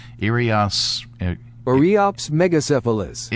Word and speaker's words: Eriops megacephalus: Let's see if I got it right. – Eriops megacephalus. – Okay, megacephalus.